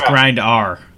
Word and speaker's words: Grindr: It's pronounced Grindr.